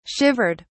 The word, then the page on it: shivered: British English